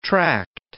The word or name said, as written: tract